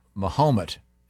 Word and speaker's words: Mahomet: And then on the 17th at Yellow and Company, and I pronounce it Mahomet, Illinois, and their team, the Fighting Alis.